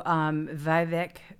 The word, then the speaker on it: Vivek: Oh, Vivek.